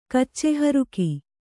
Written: ಕಚ್ಚೆಹರುಕ

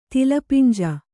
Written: ತಿಲಪಿಂಜ

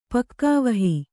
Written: ಪಕ್ಕಾವಹಿ

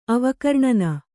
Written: ಅವಕರ್ಣನ

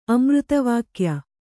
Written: ಅಮೃತವಾಕ್ಯ

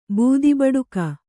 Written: ಬೂದಿಬಡಿಕ

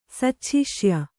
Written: ಸಚ್ಛಿಷ್ಯ

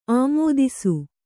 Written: ಆಮೋದಿಸು

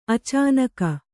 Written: ಅಚಾನಕ

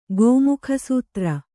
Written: ಗೋಮುಖಸೂತ್ರ

